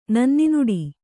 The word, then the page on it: ನನ್ನಿನುಡಿ: Kannada